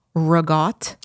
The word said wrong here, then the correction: ricotta: Ragotte. No. Ricotta.